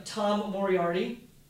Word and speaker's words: Moriarity: We have Tom Moriarty. I said that correctly. – You did.